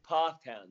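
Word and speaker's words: Pfafftown: I believe it's Pfaff Town. – Close. It's Pfaff Town, yeah.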